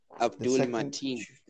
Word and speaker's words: Abdul Mateen: Abdul Mateen.